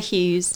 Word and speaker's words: Hughes: It's Anna Hughes.